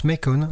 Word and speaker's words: Macon: Il passe toute son enfance à Macon, ça se prononce Macon, en Géorgie, dont il deviendra plus tard le maire d'honneur.